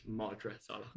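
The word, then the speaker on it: Madra Salach: Sorry, it's Madra Salak.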